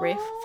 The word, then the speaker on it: Ralph: Ralph, but then but it's pronounced Rafe, Rafe.